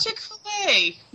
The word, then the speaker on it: Chick-fil-A: It's Chick-fil-A, not ah.